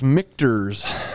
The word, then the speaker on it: Michter's: We did look it up, and it is Michter's, and it is barreled in Louisville, Kentucky, at the Michter's Distillery, LLC.